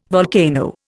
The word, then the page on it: volcano: Indian